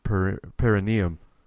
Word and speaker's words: perineum: I think it's perineum.